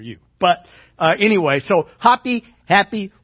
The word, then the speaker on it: Hapi: What a wonderful name, Happy. H-A-P-I. Okay, some people pronounce it Hoppy. They don't know that A is an A sound or an A sound. It's not a A sound.